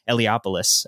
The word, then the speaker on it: Eliopoulos: and lettered by Chris Eliopoulos. I finally pronounced that right, because in our live episode we did with Fred Van Lente, he told me how to pronounce it, so that's good.